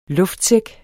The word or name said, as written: luftsæk